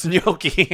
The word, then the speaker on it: gnocchi: bitch, it's gnocchi.